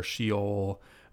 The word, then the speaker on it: Sheol: So you might say Sheol or Sheol, but I'm pretty sure the Hebrew pronunciation is Sheol, if I remember correctly, Lucas, right, from our Hebrew? – I think so.